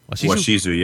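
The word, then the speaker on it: Washizu: Is that how you say it? Washishu? – Washishu, yeah.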